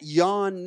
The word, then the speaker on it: Yaun: But that Yaun name, I say it like this in Langley, South Carolina, it's a four-letter word. It's a four-letter word, Y-A-U-N.